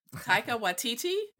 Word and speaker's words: Taika Waititi: Many fans are actually hoping that Thor Ragnarok director, I'm going to butcher his name, Eric, you can correct me, Taika Waititi? – You nailed it.